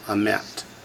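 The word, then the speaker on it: emet (אמת): Normally you accent the first Segol when there's two in a row, but not when there's a Shavah under it. So the Shavah transfers the accent to the second syllable, emet.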